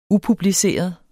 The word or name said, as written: upubliceret